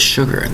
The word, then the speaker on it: sugar: Two distinct syllables spelled S-U-G-A-R. Now you'll notice with the word sugar, there's no H to give you that sh sound. The S-U is pronounced as sh, sugar. The spelling is S-U-G-A-R.